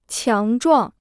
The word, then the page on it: 强壮: qiáng zhuàng